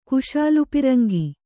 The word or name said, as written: ಕುಶಾಲುಪಿರಂಗಿ